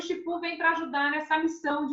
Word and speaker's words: Shippu: Então o Shipu, que é um aplicativo, ele é o se escreve S H I P P U.